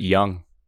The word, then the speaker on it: Jung: He pronounces it Young. – Young. Jace Young. – J-U-N-G. Jace Young. – Jace Silent Young. J turned to Y, I guess.